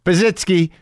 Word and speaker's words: Pazsitzky: Pazsitzky. Man, I better pronounce that right. Pazsitzky, P-A-Z-S-I-T-Z-K-Y, Christina Pazsitzky, Pazsitzky. We talk about it. I think that's it. I think I got it.